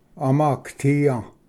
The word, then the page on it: ᎠᎹᎠᎦᏘᏯ: amaagatiya